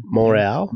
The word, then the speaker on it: Montreal: And of course, we cannot forget Montreal. Is that okay pronunciation? – Montreal. – Montreal, damn it.